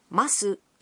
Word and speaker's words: ます: The vowel...U in su of masu is silent, so it's pronounced as mas.